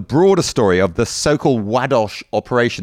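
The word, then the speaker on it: Ładoś: Now, if I was going to pronounce that in my normal way, as you all know, I'm a great mangler of pronunciations, I'd have said Ladosh, because it's L-A-D-O-S, but Roger assures me it's Wadosh. And Wadosh was the Polish ambassador in Switzerland,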